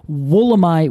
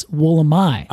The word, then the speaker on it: Wollemi: I'd say, oh, yeah, Wollemi. However, in the place where this is from, they would pronounce it the Wollemi Wilderness. So the tree is named after the wilderness, which they pronounce Wollemi.